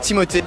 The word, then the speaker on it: Timothée: It's supposed to be Timothée. Timothée Chalamet.